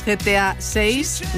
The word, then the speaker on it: GTA 6: Y hoy tenemos una cita para los amantes de los videojuegos, GTA 6.